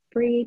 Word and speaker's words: Bree: And then also I'm becoming more active on LinkedIn, so you can find me under my name, and it's Bree, B-R-E-E, and the last name is spelled V-C-U-L-E-K.